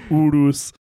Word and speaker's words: Urus: You have to say it like all guttural. Urus.